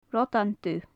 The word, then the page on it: rodan dubh: gaelic